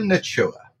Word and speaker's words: Nacua: And I'm going to ask you how to pronounce this name again, Grinch. This is Puka Nichua. – Correct. Puka Nacua.